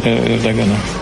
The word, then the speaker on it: Ердоган: Слово президенту Турецької Республіки господину Таїпу Ерджагану. Реджепу Таїпу Ердогану.